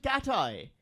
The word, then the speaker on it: Cathie: Uh, although it's C A T H I E. Right. No, it's not. It's, it's not. it's it, they, they pronounce it Catai. It's it's Cathy.